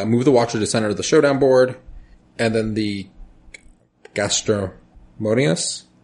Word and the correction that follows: gastrodermis: Did I say that right? – Gastrodermis. So gastrodermis. That's like gastro as in, you know, the gastrointestinal, and dermis as in skin.